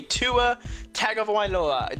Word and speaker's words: Tua Tagovailoa: It's going to be Tua Tagovailoa. Did I say that right? – Yes, you did.